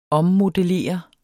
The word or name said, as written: ommodellere